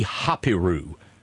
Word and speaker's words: Hapiru: Hapiru. We would spell it H-A-P-I-R-U, the Hapiru.